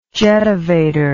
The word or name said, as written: jetavator